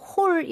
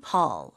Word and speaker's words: hall: 영어로는 hole 이렇게 입술이 동그래지지 않아요. – 그러면 네. 구멍이란 뜻이 돼버리잖아요. – 네. 턱이 그냥 내려가는 느낌으로 hall. hall. – 얼에 더 가깝네요.